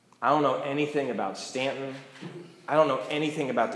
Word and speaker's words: Staunton: To which I said, "I think it's actually pronounced Staunton."